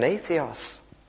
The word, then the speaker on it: atheos: It's spelt A-T-H A-T-H-E-O-S.